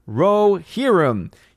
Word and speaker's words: Rohirrim: Orange Arrow View says, it's pronounced Rohirrim.